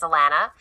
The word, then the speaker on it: Alana: So my name is Alana, and I am a co-host over on the Kingdom Sexuality podcast.